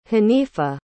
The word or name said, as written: haneefa